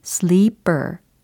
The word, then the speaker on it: sleeper: 잠자는 사람 혹은 침대차를 뜻할 수 있는 단어인데, 어, 이거 발음 혼동을 안 하셔야 돼요. 특히 sleeper. 이라는 단어하고 비교를 해 두시면 좋겠습니다. 요거 발음이 살짝 다르죠? 어, 알려드린 그 대표 단어는 sleeper 이거였고, 어, 지금 발음으로 비교 대상으로 들려드린 단어는 slipper이었습니다. 차이 느껴지시나요? 철자가 s l i p p e r 이라는 거예요. 슬리퍼 아닙니다. 슬리퍼 이거 아니고 우리는 sleeper 이렇게 연습을 해보는 거예요.